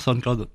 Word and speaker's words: SoundCloud: Ça s'appelle SoundCloud. Je prononce pas bien. – Tout à fait. SoundCloud. Non, SoundCloud, vous prononcez bien.